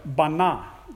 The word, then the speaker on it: bana: That word built in the Hebrew is the word, it's pronounced this way, bana, not banana, but bana. That's how it's pronounced in Hebrew.